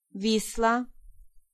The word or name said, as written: Vistula